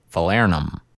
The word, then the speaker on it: falernum: And the next one is falernum. Did I say it right? Well, we're going to hear from an expert how to say it. – Falernum. Or known as velvet falernum.